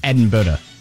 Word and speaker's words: Edinburgh: Now, I need to stop you real quick because you're pronouncing it like you're in Indiana. It's Edinburgh.